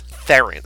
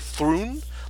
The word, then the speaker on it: Theron: In South African, it's like Thrun. Like, it's like this really sort of guttural one-syllable word or sound.